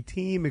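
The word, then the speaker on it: Thiem: It could be team. It could be time. – No, I'm telling you, it's team. It's not time. I agree. – It's team.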